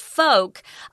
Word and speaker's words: folk: But when you talk about folk, notice I'm not pronouncing the L. I'm not saying folk. We have quite a few words like this, like yolk, poke, polka dots. There's no L in it.